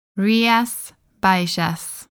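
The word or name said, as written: Rías Baixas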